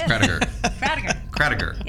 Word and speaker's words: Krattiger: Krediger.